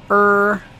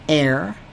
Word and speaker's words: err: Do you think it best that people err? By the way, it's not err. To err is human. No, it's to err is human. It's one of those mispronunciations that drive me nuts.